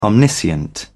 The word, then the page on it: omniscient: British English